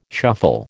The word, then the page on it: shuffle: American English